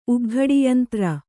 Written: ಉಗ್ಘಡಿಯಂತ್ರ

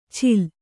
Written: ಚೀಲ

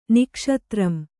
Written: ನಿಕ್ಷತ್ರಂ

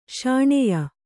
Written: ಶಾಣೆಯ

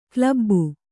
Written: ಕ್ಲಬ್ಬು

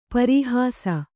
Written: ಪರೀಹಾಸ

